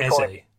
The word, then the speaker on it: Eze: Eze, is that how you pronounce it? Eberici Eze. – That's it, Eze. I like easy.